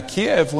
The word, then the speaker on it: Kiev: Now, Kiev, when it's spelled K-I-E-V, that's actually the Russian way of how you would say it, Kiev.